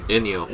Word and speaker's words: Ennio: Ennio.